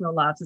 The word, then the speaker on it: Roelofs: Harriet, Harriet Roelofs, or I think it's pronounced Roloffs, it's R O E L O F S, good German word, maybe Roloffs.